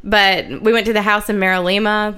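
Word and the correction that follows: MinaLima: where the, Mina Lima, I always mispronounce it, where like the people that designed...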